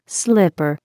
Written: slipper

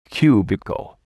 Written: cubicle

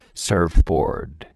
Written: surfboard

